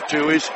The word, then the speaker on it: Toohey: Adam Toohey, spelled T-O-O-H-E-Y, pronounced Toohey.